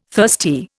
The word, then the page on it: thirsty: Indian